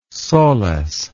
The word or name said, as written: soles